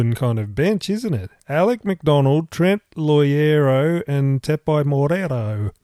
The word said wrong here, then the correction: Moroa: Jesus Christ, sorry. – You butchered it. Moroa. – Moroa. Sorry, I read that wrong.